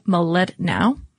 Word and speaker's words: Mlodinow: And there is a really interesting book, Leonard Medlanov. Excuse me. Let me try that again because I'm sure that at least his family would appreciate that I pronounced it correctly. But Leonard Mlodinow.